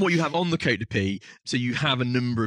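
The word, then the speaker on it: Côte de Py: Côte de Pie is literally, and when we say Pie, it's spelled P-Y,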